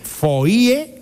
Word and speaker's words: foie: Foie, que es una palabra francesa que a mí siempre me ha llamado mucho la atención porque se escribe foie y se pronuncia foie. Fíjate, la O, la I y la E se escribe y luego se pronuncia con la U.